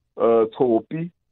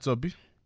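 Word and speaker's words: Tshopi: You know, but the other one, it's called Tsopi, you know. – And how do I spell that, Tsopi? – So it's T-S-H-O-P-I.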